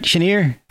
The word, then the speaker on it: Chenier: Did I pronounce that right? Chenier. – Yeah.